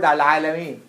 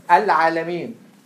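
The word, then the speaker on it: al-alameen (العالمين): It's word al-alameen. Al-alameen. Which word? Do you have something? Keep you so busy. Put it on the desk. Al-alameen. So, al-alameen is what? Al-alameen. Qamariyya, right? Yes. Because it gets pronounced.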